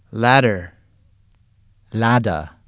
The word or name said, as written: ladder